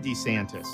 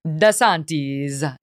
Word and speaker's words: DeSantis: He points out that the proper Italian pronunciation would be DeSantis, Ron DeSantis. But a lot of Italians make it a little bit more Americanized and might just go with DeSantis.